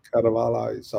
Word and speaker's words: Carvalhais: En Cruzado White från Quinta dos Carvalho. Jag kan inte uttala det. Caravajas. Caravajas. Carvalhais, ja.